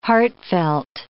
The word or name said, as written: heartfelt